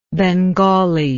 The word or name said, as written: bengalee